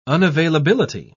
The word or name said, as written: unavailability